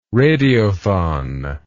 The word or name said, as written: radiothon